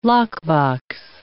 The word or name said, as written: lockbox